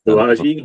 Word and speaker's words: Balaji: So speaking of that, let's talk about this latest prediction by I don't even know how to say his name, Balaji. – Yeah, Balaji, maybe. – Balaji, yeah.